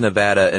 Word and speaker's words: Nevada: Yeah, they say it's Nevada, and my response is always the same.